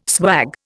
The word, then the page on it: swag: Indian